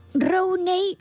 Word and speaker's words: Rooney: Hubo también otro futbolista Rooney, se dice así.